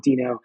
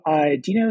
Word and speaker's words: Deno: So yeah, before we dive into new things that are coming to the project, can you tell us about what Deno, it's going to be Deno in my head forever. I know it's one of those where half the community, I feel, still is saying it incorrectly.